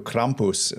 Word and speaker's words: Krampus: if you don't know who Krampus, that's a correct pronunciation there.